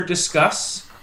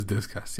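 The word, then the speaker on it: Disqus: Just sign up for Discuss. Is that how you pronounce that, guys? Discuss? – For a long time, I was saying Discus, but I believe it is Discuss, yes.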